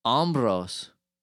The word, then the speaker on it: Hombros: Hombros. – Yes. – Like hombre, but with no? Hombros? – Yes. – And is it spelled the same? H-O? M-B-R-O-S? – Exactly the same.